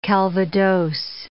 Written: calvados

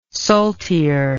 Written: saltier

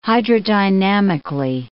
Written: hydrodynamically

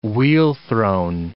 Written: wheel-thrown

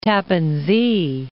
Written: Tappan Zee

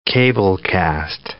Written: cablecast

